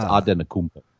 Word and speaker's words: Antetokounmpo: So, because it's not a Greek name, it's a Nigerian name. It's Antetokounmpo.